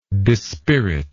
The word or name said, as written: dispiritingly